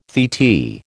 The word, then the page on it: titi: American English